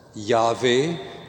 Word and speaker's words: Yahweh: And it is written with four letters, which we pronounce as Yahweh, but since God's holy name is never pronounced by Jews or Christians, we say Lord.